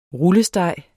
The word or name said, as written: rullesteg